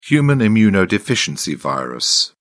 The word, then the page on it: human immunodeficiency virus: British English